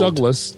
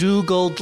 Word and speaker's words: Dougald: Well, you say that you say that as though Dougald is more obvious a pronunciation than Dougald. – D O U G A L D, Lamont, right. Dougald Lamont of Winnipeg. – Oh, it's a Canadian name, so of course yeah. it's pronounced Dougald.